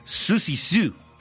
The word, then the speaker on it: Siouxsie Sioux: Se pronuncia, Siouxsie Sioux.